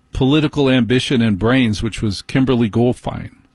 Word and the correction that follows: Guilfoyle: How do you pronounce? What? You're kidding. Guilfoyle? That's how you say it? Kimberly Guilfoyle?